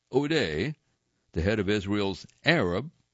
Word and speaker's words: Odeh: Iman, first name is spelled A-Y-M-A-N. Last name, O'Day, is spelled O-D-E-H.